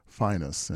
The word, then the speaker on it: Phineas: Now, his name is spelled P-H-I-N-E-A-S and looks to be Phineas, but it says in the liner notes that he preferred to pronounce his name Phinehas, and it's spelled phonetically F-I-N-E-U-S with the accenting the first syllable.